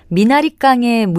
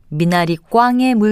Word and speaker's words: 미나리꽝: 먼저, 미나리깡의 물대기와 미나리꽝의 물대기 중에서 맞는 표현, 어느 것일까요? 미나리를 심는 논을 뜻하는 표현은 미나리꽝이 맞습니다. 발음을 편하게 하는 미나리 깡으로 잘못 알고 사용하는 일이 적지 않은데요. 미나리 꽝이 올바른 표현입니다.